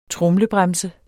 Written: tromlebremse

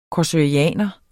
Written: korsoraner

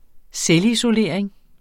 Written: selvisolering

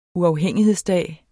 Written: uafhængighedsdag

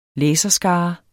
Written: læserskare

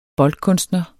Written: boldkunstner

